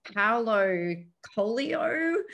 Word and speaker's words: Paulo Coelho: It's Paulo Coelho.